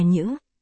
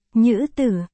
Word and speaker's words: nhữ: Du, do đó có người cho rằng chữ nữ này cũng nên đọc là nhữ, thế nên. Nữ tử, đọc là, nhữ tử, Nghĩa là các ngươi.